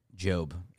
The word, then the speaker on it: Job: Job. It is... – It's spelled J-O-B, but it's Job.